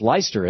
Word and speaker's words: Leicester: Hey, or that's pronounced Leicester, isn't it? – Yeah, that's what everyone says, but it's Leicester. – Leicester, Leicester, Mass. Yeah. Okay, what's up? – Like Leicester, England.